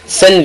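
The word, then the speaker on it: Cène: C'est C-E-N-E. – Sen, c'est comme tu entends sen là, sen, mais tu mets E à la fin.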